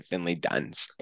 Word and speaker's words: Finley Dunes: I go with Dunes. Because, like, it's more fun to say Dunes, but it's definitely Finley Duns.